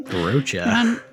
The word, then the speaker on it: Rocha: Emphasis on the Roach. – I don't know how to say it. I don't know how to say his name. – Rocha. – Ron Rocha. R-O-C-H-A.